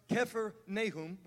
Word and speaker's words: Kephar Nahum: Capernaum, actually pronounced Kephar Nahum. We say it wrong.